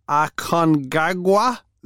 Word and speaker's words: Aconcagua: And then it's Mount Vinson, Mount Elbrus, Kilimanjaro, Denali, Aconcagua, is that right? – Yeah, that's right.